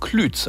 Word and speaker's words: Klütz: Aber auch Lübs und Klütz, also gesprochen mit langem Ü, die werden immer mal wieder zu Lübs und Klütz.